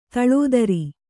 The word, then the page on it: ದಿಲ್ದಾರ: dildāra